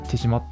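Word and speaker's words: 豊島: と豊かな島と書いて手島って読みます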